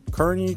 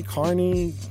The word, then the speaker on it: Kearney: The Oracle that is one Oren Kearney. Carney.